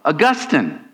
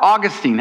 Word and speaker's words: Augustine: And it actually comes from Bishop of Hippo, Augustine, or Augustine, however you want. I've never figured out exactly how to say it, so I'll say it both ways.